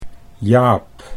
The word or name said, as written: Jaap